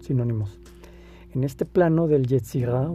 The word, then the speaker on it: Yetzirah: Y la palabra con, la palabra con la que ellos lo definen es, con Y, con Z y con H al final. Yetzirah. Es una pronunciación un tanto como el francés que es gutural. Yetzirah.